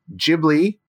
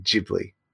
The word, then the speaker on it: Ghibli: the upcoming Ghibli.